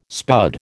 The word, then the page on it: spud: American English